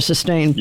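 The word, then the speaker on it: Systane: I think it's pronounced Sustain. Right. – That's over-the-counter stuff. Yeah, Sustain.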